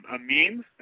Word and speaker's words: meme: Are you familiar with what a mime is? – Yeah, like the mimes that don't speak? – No, M-E-M-E. Maybe I'm pronouncing it wrong. – A meme? I think it's pronounced meme.